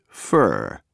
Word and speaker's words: for: The word for is often pronounced fur when it is unaccented.